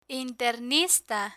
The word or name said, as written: internista